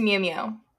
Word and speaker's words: Miu Miu: It's Miu Miu. Miu Miu.